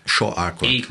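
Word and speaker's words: Shawn Achor: Do Sean Arcor. Ele fala que... – Ou você... na pronúncia correta, Shaw... – Shaw Arcor. – Acor. – É isso, é que nem Austin.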